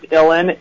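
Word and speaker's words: Uilleann: We also have Preston Howard, who's on the Illan pipes, which it's an Irish spelled word, so while it's pronounced Illan, it definitely is not, phonetically, it's nowhere near spelled like that.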